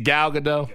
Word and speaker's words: Gal Gadot: And I, much like when Wonder Woman and DC Universe came out with Wonder Woman with Gal Gadot, I thought that there were other people I would have chosen. But I think is it Gal Gadot? – Yeah. – Thank you.